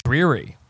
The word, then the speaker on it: Drury: Or Drury. – They don't even. – It's a Drury Inn. I stayed at a Drury Inn with me and Brock one time. – You say it's dreary.